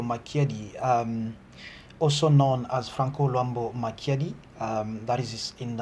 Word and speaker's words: Franco Luambo: pronounce i can pronounce at least the first part of this it's uh it's Franco Luambo.